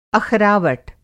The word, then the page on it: अख़रावट: Hindi